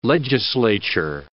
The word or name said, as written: legislature